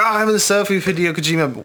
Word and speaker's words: Hideo Kojima: And when Hideo Kojima, is that how you pronounce his name? – Yeah.